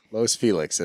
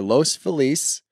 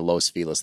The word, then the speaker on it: Los Feliz: Los Felix, as Shooter calls it. Either Los Feliz or Los Felix were his two beginning options. We got it down to Los Felix. That's how the locals say it.